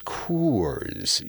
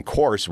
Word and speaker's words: Coors: you've you've always got people telling you it's Coors. You see, you got to get the you got to get the uh, long, you got to get the two O's in, and uh, and and if you mispronounce it by going course, well then you're wrong and you're going to be corrected.